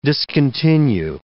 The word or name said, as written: discontinue